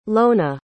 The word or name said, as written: lona